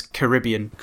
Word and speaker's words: Caribbean: Except you've got to say it like an American, so it's Caribbean.